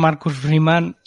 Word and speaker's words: Freeman: Sí, lo de nuestro amigo Marcus Freeman. Sí. Lo, lo he dicho bien eh, hoy, esta semana. Sí, sí, hoy, hoy bien.